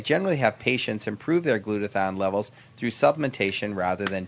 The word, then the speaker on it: glutathione: And before the break, your host, was talking about the benefits of glutathione. So, did I pronounce it right? – Yes, you did.